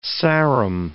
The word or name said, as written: Sarum